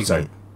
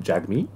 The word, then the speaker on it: Jagmeet: Jagmeet. Yeah, but you, but you pronounce it Jug.